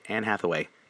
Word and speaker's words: Hathaway: Did I say her name right? Yeah, I did. Anne Hathaway.